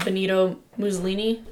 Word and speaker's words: Benito Mussolini: And they theorized this because George was very outspoken about his thoughts about Benito Mussolini, that's how you say it, who was an Italian politician and journalist who founded and led the National Fascist Party.